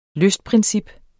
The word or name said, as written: lystprincip